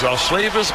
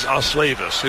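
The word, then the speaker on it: Osleivis: Now it is Oslavis Basabe.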